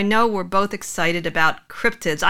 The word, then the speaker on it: cryptids: I used to think they were called cryptoids for the longest time. I've called them cryptoids. So they're like, oh, it's cryptids. I've been saying it wrong for 10 years.